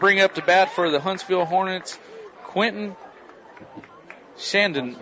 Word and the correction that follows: Chandonnet: Quentin Chandonnet. – Make sure we get that pronunciation right.